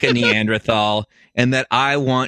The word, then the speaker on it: Neanderthal: Yes, it's pronounced Neanderthal. It comes from the Neander Valley of Germany.